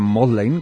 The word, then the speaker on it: Magdalen: El Magdalen, que en realidad se pronuncia Magdalen como excepción, es uno de los más cinematográficos.